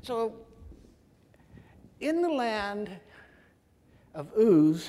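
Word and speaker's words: Uz (עוּץ): Yeah, the Hebrew word would be pronounced Uz, but we're going to get into some Hebrew words here in a little bit.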